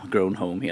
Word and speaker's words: Grown Home: Ut, uttalar jag det korrekt? Det känns som jag frågar en, så här, utländsk eh, dignitär som kommer in. Så här, Ursäkta, uttalar jag det korrekt? Eh, ja, Grown Home heter spelet.